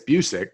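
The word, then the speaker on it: Buczek: I still have so much trouble with pronouncing his last name. – It's Busick, because there's a very famous,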